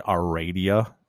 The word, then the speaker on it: Heredia: So did I say that right? Aradia, Costa Rica? – Yes, that's right.